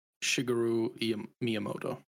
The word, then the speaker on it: Shigeru Miyamoto: Shigeru Miyamoto.